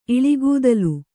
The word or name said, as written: ಇಳಿಗೂದಲು